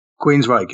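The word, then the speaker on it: Queensrÿche: I've got to go with Prince, the Queen, the is it Queensryche? Is that how you say it? – Queensryche.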